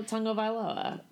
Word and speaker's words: Tagovailoa: So, the Miami Dolphins will be starting their rookie quarterback, Tua Tagovailoa. – I don't know if I pronounced that right. Oh, my God. I practiced that so many times. – Tagovailoa.